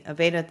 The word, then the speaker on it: Avada: So one of the website themes that I love to use, it's spelled A-V-A-D-A, Avada theme, or Avada, depending on how you want to pronounce it.